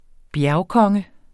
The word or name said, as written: bjergkonge